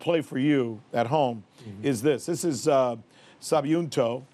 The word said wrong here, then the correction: Subianto: right? Did I pronounce his name right? – I'm going to... Subianto. – Subianto.